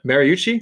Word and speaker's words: Mariucci: How do you say his last name? I think you nailed it. Mariucci, yeah, like coach.